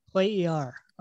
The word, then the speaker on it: Cuellar: But they are bringing in potentially, maybe, a defensive midfielder, Gustavo Quer, who's Colombian, plays for Al Hilal. I said that wrong, didn't I? – Cuellar. – Okay. – Cuellar.